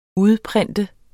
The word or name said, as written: udprint